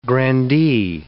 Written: grandee

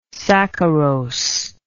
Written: saccharose

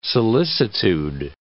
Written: solicitude

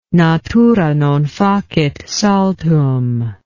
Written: natura non facit saltum

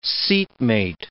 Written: seatmate

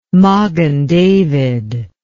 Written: magen david

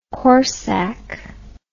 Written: corsac

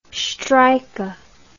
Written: streicher

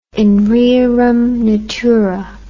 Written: in rerum natura

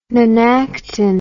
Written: nonactin

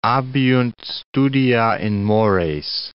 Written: abeunt studia in mores

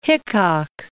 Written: Hickok

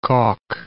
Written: Kock

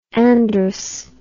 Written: androus

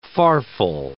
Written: farfal

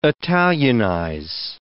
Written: italianize

